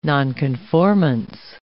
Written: nonconformance

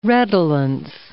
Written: redolence

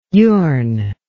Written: yourn

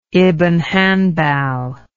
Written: ibn hanbal

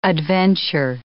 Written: adventure

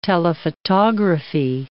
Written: telephotography

